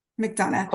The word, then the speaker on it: McDonough: He got his MBA from Georgetown University's McDonough School. It's McDonough, right? Did I say that right? – McDonough.